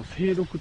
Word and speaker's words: 精読: 速読とは違うんだけど、でも速読までいかなくてもなんかその、よく、その精読って言いますよね。その精査とか精算の、精に読むと書いて精読って読みますけど、精読ばかりでもしょうがない。